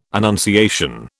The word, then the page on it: annunciation: British English